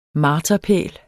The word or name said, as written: marterpæl